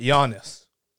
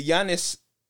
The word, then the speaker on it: Giannis: But Giannis is spelled G-I-A. – Yeah, but the G. – G silent. – You're like Jan, not John.